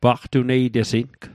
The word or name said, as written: paqtuneitesing